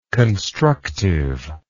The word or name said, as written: constructiveness